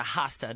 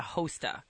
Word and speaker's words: Hosta: I actually have to say one of the first things I learned being the producer for the Mike Novak Show was the proper way to pronounce Hosta. It's a Hosta, not a Hosta.